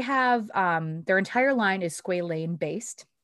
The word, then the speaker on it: squalane: S-Q-A-L-A-N-E. Right. Sorry. – So it is the A. – Squalane.